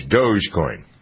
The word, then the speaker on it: Dogecoin: Dogecoin. Yeah, you did it. Dogecoin.